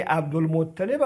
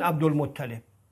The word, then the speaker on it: عبدالمطلب: حالا برخی از دوستان عبزوی هم عبدالمطلب تلفظ میکنن. ما تطلیب تو زبان عربی نداریم، یعنی باب تفعیل در این وزن در این قالب کاربرد نداره، ولی اطلاب کاربرد داره. یعنی عبدالمطلب تلفظ ضبط نسب شناسان مطلب هست نه مطلب.